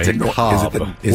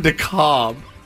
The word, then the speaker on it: DeKalb: DeKalb. – Where? – It Northern DeKalb. – Oh, it's spelled DeKalb. – K-A-L-B. Yes.